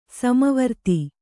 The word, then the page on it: ಸಮವೃತ್ತ: kannada; sama vřtta